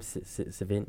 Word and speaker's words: Sevigny: How do you say her last name? – Sevigny.